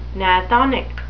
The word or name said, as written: gnathonic